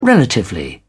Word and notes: relatively: British English

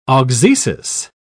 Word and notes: auxesis: British English

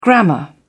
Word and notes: grammar: British English

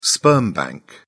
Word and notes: sperm bank: British English

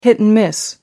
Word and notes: hit and miss: American English

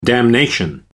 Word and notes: damnation: American English

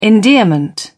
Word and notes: endearment: British English

